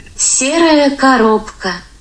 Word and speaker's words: Серая коробка: But yeah, rather than murdering that phrase, here is how Google pronounces grey box in Russian. – Серая коробка.